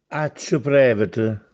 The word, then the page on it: Accerè: Castellanetano